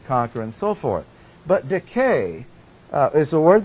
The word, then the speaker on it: Dike: And the Greek goddess of justice was Dike, D-I-K-A. Accent on the first syllable. And the A is long, Dike.